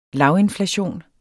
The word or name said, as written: lavinflation